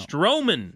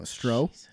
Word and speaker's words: Strowman: Stallman? No. – Good enough. – Stallman. – No. Strowman. There's an R in there. – Strawman? – No, Stroh.